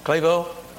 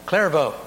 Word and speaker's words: Clairvaux: I'm not sure my French pronunciation, but C-L-A-I-R-V-A-U-X. Clairvaux? – Clairvaux. – Clairvaux, thank you.